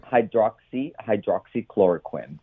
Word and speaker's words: Hydroxychloroquine: Hydroxychloroquine. – Okay. – Hydroxychloroquine, to break it up apart.